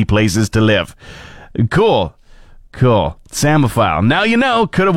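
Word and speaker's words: psammophile: To let you know how it is spelled, P-S-A-M-M-O-P-H-I-L-E. I heard the word before I saw it spelled, so I immediately thought samophile.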